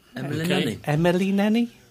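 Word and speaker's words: Emily Nenni: I'm going to say her name is Emily Nenny. – Emily Nenny. – Sounds all right. – Emily Nenny. That's it, yeah.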